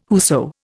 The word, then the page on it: whoso: Indian